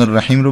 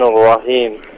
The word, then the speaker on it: الرحیم: یه مقداری واضح‌تر، چون ح باید گرفته باشه دیگه، با صدای گرفته باید محی جیمی رو ادا بکنیم، یعنی مخرج حرف ها جوری هستش که حالت گرفتگی و فشردگی ایجاد میکنه در لحظه تلفظش. الرحمن الرحیم بگید یه بار. – از اول بخونم؟ – نه همین الرحمن الرحیم رو بگید. – الرحمن الرحیم. – آها، الرحمنش خوب شد اما رحیمش خوب نشد.